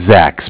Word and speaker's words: Zach: Zach, spelled Z-A-C-H. – Okay, from what bank? – Bank of Cardiff. Cardiff is spelled C-A-R-D-I-F-F, as in Frank Frank.